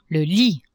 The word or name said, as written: lit